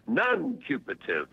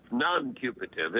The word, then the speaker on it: nuncupative: The word of the day is non-cupative. Non-cupative. It's a peculiar pronunciation. N-U-N, two N's there at the beginning. N as in no. N-U-N, followed by C-U-P-A-T-I-V-E. Non-cupative. N-U-N-C-U-P-A-T-I-V-E.